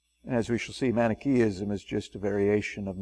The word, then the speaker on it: Manichaeism: This is pronounced Manichaeism. Manichaeism.